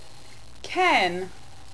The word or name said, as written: cairn